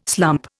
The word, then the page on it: slump: Indian